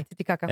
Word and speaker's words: Titicaca: As a kid, I remember that name, Lake Titicaca. Am I saying it correctly? – Yeah, Titicaca.